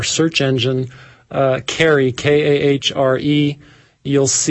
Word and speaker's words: Kahre: The gentleman's last name is spelled K-A-H-R-E, but I believe he pronounces it Carey.